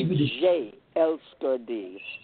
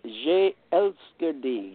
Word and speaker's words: Jeg elsker dig: I was getting ready to say in Danish or in Denmark, we'd have to say "Je elsker dig." – How do you say it? "Je elsker dig." – Oh, "Je elsker dig."